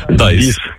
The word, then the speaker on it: Dies: Так, тільки англійською дієс, як читається. От пишеться D-I-E-S. Дайс.